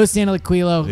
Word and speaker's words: Santaliquido: Like, who knows who Joe's I can never pronounce his name now because I always say Joe Santalagilio. – Joe Santaliquido.